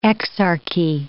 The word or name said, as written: exarchy